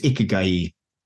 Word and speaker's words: ikigai: The word is ikigai. So I'll probably mispronounce that for the rest of this podcast, but that was how his Japanese friends sort of told me this morning.